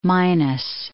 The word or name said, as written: Minas Basin